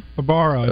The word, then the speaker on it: Barbaro: Is it Barbaro? – Barbaro, I believe is how it's pronounced. – Barbaro, yes.